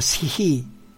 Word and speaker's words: Shih: Y el último de estos dos hexagramas se llama Shi, que se escribe Shih, y significa el ejército, donde el agua está abajo y arriba está la tierra.